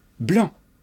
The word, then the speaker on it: blanc: Blanc. Prononcer blanc. On ne prononce pas le C.